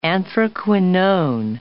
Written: anthraquinone

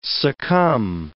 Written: succumb

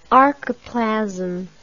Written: archiplasm

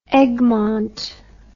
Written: Egmont

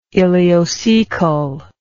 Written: ileocecal

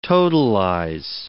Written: totalize

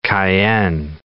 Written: cayenne

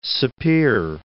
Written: Sapir

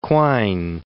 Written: Quine

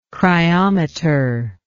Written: cryometry

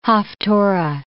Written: haftorah

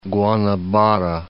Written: Guanabara Bay

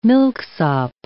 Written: milksop

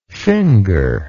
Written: fingerer

